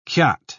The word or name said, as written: kyat